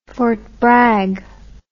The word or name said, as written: Fort Bragg